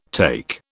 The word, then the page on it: take: British English